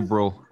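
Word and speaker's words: Brühl: Daniel Bruhl. Daniel Bruhl.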